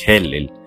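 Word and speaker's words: Helel (הילל): Aquí la palabra lucero de la mañana es Helel, se pronuncia así Helel, la cual significa mm, el brillante o el que brilla.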